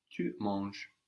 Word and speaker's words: tu manges: This has an S on the end, but of course we don't hear it. Tu manges.